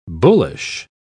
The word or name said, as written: bullish